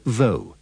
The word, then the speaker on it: though: Pay attention to the pronunciation of the words although and though. The O-U-G-H at the end is pronounced with the sound of the O in go.